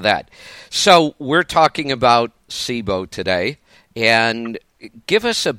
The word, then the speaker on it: SIBO: SIBO or SIBO? – Typically it's SIBO. – SIBO. I've been the one saying it wrong all this time.